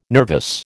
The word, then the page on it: nervous: American English